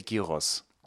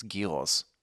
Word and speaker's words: gyros: We say gyros. – Gyros? – Yeah, it's got a G. You pronounce it. The G is not silent. It's not it's not like a ninja. You can say it. It's gyros.